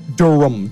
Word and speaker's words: Durham: By the way, I've wanted to call this movie Bull Durham the whole time just because of the way it's spelled. It's spelled with ham, actually.